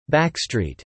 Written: backstreet

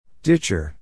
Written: ditcher

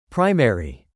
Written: primary

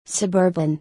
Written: suburban

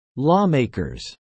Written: lawmakers

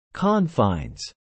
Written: confines

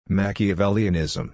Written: Machiavellianism

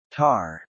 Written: Tar

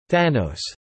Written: Thanos

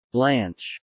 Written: blanch

